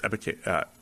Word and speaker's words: Ebiketie: It's Ebiketie. E-B-I-K-E-T-I-E.